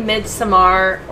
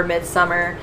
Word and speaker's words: Midsommar: People say Midsommar or Midsummer. I know that Midsommar is a good pronunciation for, like, actual certain cultures. They do have, like, a Midsommar festival or celebration.